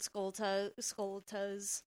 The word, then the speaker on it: Skulltula: Skulltulas. – Because it's supposed to be like tarantula, right? – Yeah, I think so. Like Skulltula.